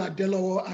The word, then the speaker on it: Adelowo: Well, my name is Samuel Adelowo, and you pronounce it beautiful.